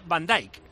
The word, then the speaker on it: Van Dijk: Y De Ligt se pronuncia De Ligt y Van Dijk se pronuncia Van Dijk. – ¿Ah, sí? Sí, eso dice este oyente, que la IJ se pronuncia I y Quit se pronuncia Caut.